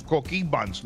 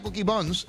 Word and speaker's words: Coquí Buns: Coquí Buns, no deje de ir a Coquí Buns en Aguadilla, lo busca así. Coquí, se escribe Coquí como el de esto, B-U-N-S, Buns, Coquí Buns.